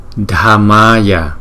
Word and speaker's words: Dhammaya: Chữ D, chữ H chúng ta đọc là da, da, da, da. Cho nên là chữ này đọc là da, da, Buddhaya, Buddhaya, Buddhaya, Namo Dhammaya, Dhammaya, Dhammaya, Namo Sanghaya, Sanghaya, Ga, Ga, Ga, Ga, Ghaya, Sanghaya, Namo Sanghaya.